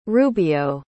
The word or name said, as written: rubio